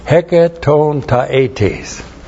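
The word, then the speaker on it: Hecatontaetes (ἑκατονταετής): Hecatontaetes. And that means 100 years old. Hecatontaetes, 100 years old. If you ever get to that point, that's I'm going to write you, send you a card. You Hecatontaetes.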